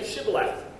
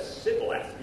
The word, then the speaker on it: Shibboleth: If he replied no, they said, all right, say Shibboleth. And if he said Sibboleth, because he couldn't pronounce the word correctly, they seized him and killed him at the fords of the Jordan.